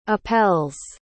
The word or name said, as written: apelles